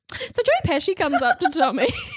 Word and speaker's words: Pesci: Pesci.